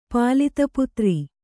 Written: ಪಾಲಿತಪುತ್ರ

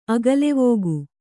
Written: ಅಗಲೆವೋಗು

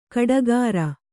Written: ಗುಟ್ಟುಗಾರ

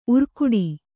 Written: ಊರೆಗೊಡು

